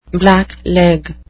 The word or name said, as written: blackleg